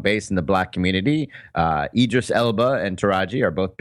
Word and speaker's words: Idris Elba: I hope you said Idris Elba or Idris Elba correctly. – Oh, Idris Elba. Okay, thank you.